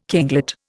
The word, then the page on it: kinglet: Indian